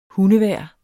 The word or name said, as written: hundevejr